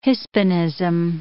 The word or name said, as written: hispanism